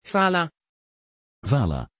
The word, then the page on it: vala: Dutch